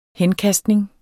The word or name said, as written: henkastning